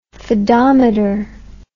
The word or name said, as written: fadometer